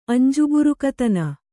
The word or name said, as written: ಅಂಜುಬುರುಕತನ